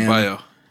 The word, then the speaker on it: Adebayo: Bam Adebayo.